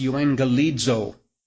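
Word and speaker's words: euangelizo (εὐαγγελίζω): First Greek word is a Greek word that would be translated and pronounced euangelizo. Euangelizo.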